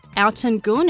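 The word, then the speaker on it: Altın Gün: So this one here is from Alton Gunn. It's spelled Altin Gun.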